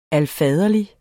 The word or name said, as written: alfaderlig